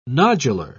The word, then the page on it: nodular: British English